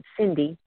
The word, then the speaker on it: Cyndy: They can also send me an email at Cyndy, CYNDY.